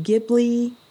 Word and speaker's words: Ghibli: Or the studio, is it Ghibli? Is that how you say it? – Mm-hmm.